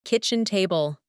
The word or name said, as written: kitchen table